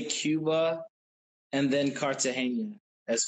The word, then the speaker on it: Cartagena: We were going to Cartagena. Is that how you say it? – Cartagena.